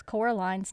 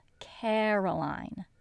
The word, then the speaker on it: Coraline: They also always mispronounce Coraline's name. It's Coraline, not Caroline.